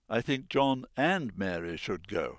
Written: John and Mary

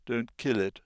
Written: kill it

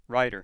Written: writer

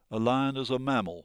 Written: lion

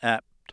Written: apt

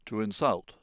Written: to insult

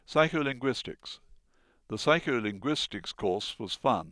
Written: psycholinguistics

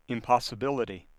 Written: impossibility

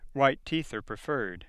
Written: teeth

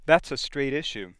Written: issue

